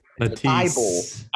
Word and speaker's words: Matisse: Hey, can you pronounce that first name again? – Mattis, I can never say it right. – Matisse.